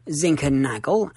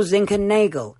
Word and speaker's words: Zinckernagel: Zinckernagel, as in Annus Horribilis, which is what the Queen called 1992, or Zinckernagel, as in Anus Horribilis, which is what the people at that nightclub in Scotland saw when Michael Gove turned up and started getting jiggy with it.